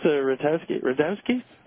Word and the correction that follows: Rutkowski: Rutkowski. – Rutkowski.